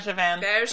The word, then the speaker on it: Bergevin: In the press Bergevin. It's a soft G, buddy.